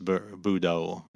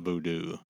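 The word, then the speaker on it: Vodou: And it's pronounced Budo. It's not voodoo.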